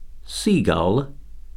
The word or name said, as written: seagull